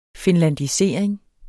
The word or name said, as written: finlandisering